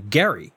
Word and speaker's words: Gerry: And he pronounced his name with a hard G, Gerry.